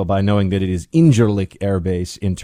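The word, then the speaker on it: İncirlik: Incirlik, because in Turkey, the sea with the little thing underneath is like a J. Incirlik. And if you say it a little bit like Borat, you sound a little bit more Turkish.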